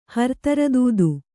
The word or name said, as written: ಹರತರದೂದು